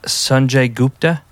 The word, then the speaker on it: Sanjay Gupta: I was listening to a podcast with Sanjay Gupta. Am I saying his name right? Yeah.